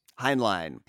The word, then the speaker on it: Heinlein: Yeah, so this is an adaptation of famous, famous sci-fi writer Robert Heinlein, however the fuck you pronounce his name, his book, Starship Troopers. – Heinlein, yes.